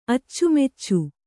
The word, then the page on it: ಅಚ್ಚುಮೆಚ್ಚು: Kannada